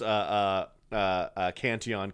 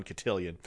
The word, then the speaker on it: Cantillon: trying to pronounce Cantillon Cotillion.